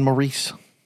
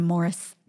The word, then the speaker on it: Maurice: The Gibb brothers are Robin and Maurice. – They called him, I guess it's the English pronunciation. They called him Morris. – Morris? It looks like Maurice.